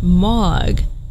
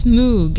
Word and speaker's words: Moog: Sorry, it's because Bruce had said Moog, pronounced Mog, but it's double O, so it's Moog. It will always be Moog.